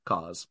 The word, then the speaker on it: C.O.S.: CAUSE, pronounced CAUSE, stands for Collection of Seeds. That's C-O-S.